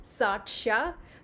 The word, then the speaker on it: Satshya: Uh, people often find my name quite hard to pronounce. So it's Saksha. It means witness in Malayalam.